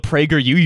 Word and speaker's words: PragerU: and you can see all the PragerU university videos you want? – Mm. Yeah, it is PragerU, just emphasis on the U.